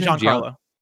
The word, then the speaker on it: Giancarlo: Giancarlo.